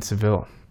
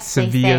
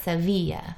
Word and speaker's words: Sevilla: But really, so Seville, a lot of people would say Seville because it's spelled S-E-V-I-L-L-E. – So Seville and Sevilla, they're actually the same. – Yes, but Sevilla is the right way to say it. – They say it Sevilla.